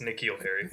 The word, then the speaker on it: N'Keal Harry: I said it backwards, didn't I? It's Keneal Harry. – Well, that's what you said, but yeah, it's N'Keal Harry.